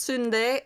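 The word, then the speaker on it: sundae (순대): So if it's Romanized, if the menu has the English, they think they're being helpful by putting, you know, the English underneath, it will be spelled exactly like sundae, yeah. – But it's pronounced sundae and it's pig intestines, essentially.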